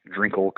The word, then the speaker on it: Drinkall: It's just at Drinkall Coach, and my last name is spelled uh, like drinking all back to back.